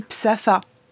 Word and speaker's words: Sappho: Elle-même, dans ses œuvres, quand elle s'appelle, quand elle parle d'elle, elle dit Psapha. Donc vous avez quelques traductions où on garde cette manière de prononcer.